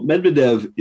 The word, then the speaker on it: Medvedev: Medvedev. – Yeah. Remind us who he is and then tell us what he has recently said. – Well, Medvedev is the former president of Russia.